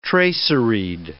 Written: traceried

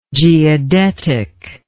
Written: geodetically

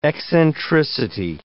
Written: eccentricity

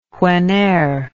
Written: wheneer

